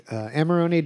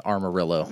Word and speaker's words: Amarone: Amarone del Valpolicella. – I'm glad that you pronounced Amarone because I was just going straight with Amarillo.